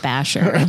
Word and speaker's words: Bashor: Basher.